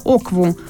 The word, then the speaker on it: окву: Или возьмем слово «окву», что на игбо значит «слово». Оба слога должны произноситься высоким голосом, как две относительно высокие ноты. Но если на втором слоге снизить звучание голоса, то получится другое слово, означающее «кучу».